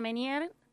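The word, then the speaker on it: Ménière: Aparte, qué palabra un poquito extraña, que tiene que ver con qué es el síndrome de Menier. ¿Se pronuncia de esa forma? – Síndrome de Menier, claro, porque se recuerda a un médico francés que se llama Prosper Menier, que fue quien descubrió los síntomas de esta enfermedad alrededor de 1861, y por eso se debe su nombre.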